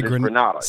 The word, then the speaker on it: Granada: Moors, Granada, G-R-E-N-A-D-A, or is it G-R-A-N-A-D-A? – You mean like Grenada? – I mean, we say Grenada in Mississippi. – Grenada or Granada. – We say Granada. – South. Pronounced Granada. – Yeah. It was this little city in Mississippi. That's Grenada.